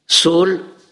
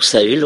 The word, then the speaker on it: Saul: Donc, Dieu dit à Samuel, Saul, ou Saül, on peut le prononcer comme ça, je l'ai rejeté.